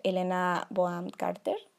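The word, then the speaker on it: Helena Bonham Carter: Es esta otra, también sale Elena Boam Carter, así se pronuncia.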